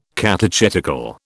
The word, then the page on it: catechetical: British English